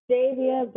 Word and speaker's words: Davia: It's Davia, but I do realize Americans say Davia, so I've just gotten Davia, and I apologize.